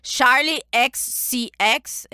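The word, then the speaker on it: Charli XCX: Charlie XCX, é isso? – Sim, sim.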